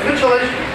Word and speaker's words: Mitscherlich: Try to pronounce it. – Mitscherlich, very good. So it's Mitscherlich.